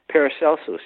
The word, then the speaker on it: Paracelsus: Paracelsus, yes.